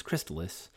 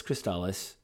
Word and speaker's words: Crystalis: and I've heard some people online pronounce this game as Crystalis, but it's Crystalis because that's originally how they pronounced it in the commercial, and that's what I'm going for because, you know, it's like an official product, and that's obviously what Nintendo has to do.